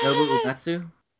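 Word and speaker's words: Nobuo Uematsu: Nobu Uematsu.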